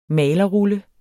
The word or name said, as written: malerrulle